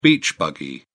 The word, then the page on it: beach buggy: British English